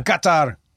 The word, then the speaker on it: Qatar: Qatar. Thank you, Karen, for the pronunciation. Make sure we got it right.